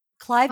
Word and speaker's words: Cliveden: That Cliveden House is that how she, how you say it? Clifton House. Cliveden. Cliveden. Yep.